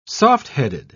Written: softheaded

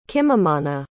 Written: kimamana

